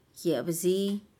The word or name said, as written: yebzi